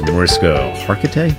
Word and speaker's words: Mariska Hargitay: And he really loves Mariska Hargitay.